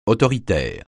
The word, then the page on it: autoritaire: francais